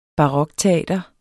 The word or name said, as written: barokteater